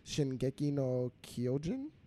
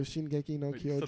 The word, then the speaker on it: Shingeki no Kyojin: So the actual name of it is Shingeki no Kyojin. – Okay. – I'm gonna sit that there. Yeah. – I'm gonna say that you said it right. – Yeah. Me too. Shingeki no Kyojin.